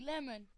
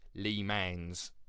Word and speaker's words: Le Mans: It sounds like lemon. – No, it's produ- produced lemon. – No, well, it, it looks like it. It's spelled L E space M A N. So really, it's Lee Mans. – No, it's lemon.